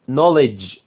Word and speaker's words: knowledge: knowledge الكيل الأولى بتاعت knowledge دي silent silent يعني لا تنطق، of knowledge شيل الكي، were covered يعني غطت، by بواسطة these missions اللي هي ايه البعثات.